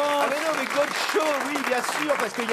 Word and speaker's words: Godchaux: Ah non, mais là, ça ne s'écrit pas Gaucho. C'est Gaucho comme Gaucho. G-O-D-C-H-A-U-X, Gaucho.